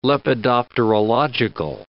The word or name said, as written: lepidopterological